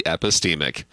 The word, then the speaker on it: epistemic: And it might look like it rhymes with epidemic, but it is actually epistemic.